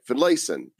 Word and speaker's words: Finlayson: Finlayson.